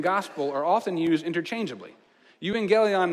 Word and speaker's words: euangelion: did you know that the word for good news in Greek is euangelion? Euangelion, it's spelled E-U. Can you say that with me on the count of three? One, two, three, euangelion.